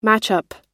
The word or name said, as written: matchup